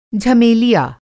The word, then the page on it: झमेलिया: Hindi